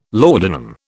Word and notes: laudanum: British English

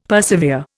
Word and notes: persevere: Indian

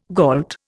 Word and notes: gault: Indian

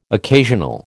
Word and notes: occasional: American English

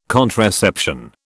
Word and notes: contraception: British English

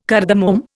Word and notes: cardamom: Indian

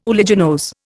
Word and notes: uliginose: Indian

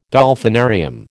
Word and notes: Dolphinarium: American English

